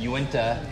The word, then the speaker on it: Uinta: Uinta.